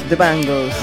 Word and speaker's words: The Bangles: The Bangles. – The Bangles.